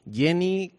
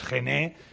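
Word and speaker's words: Gen-E: Bueno, el nombre en inglés correctamente se pronunciaría Jenny, uh-huh. Pero bueno, lo podemos decir como quieras, Gené, porque es de generación eléctrica, vamos, es igual, nos pasa igual con el Mach-E.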